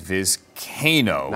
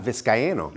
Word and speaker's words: Vizcaino: Apparently, it's Vizcaino. – It is Vizcaino. – Not Vizcaino.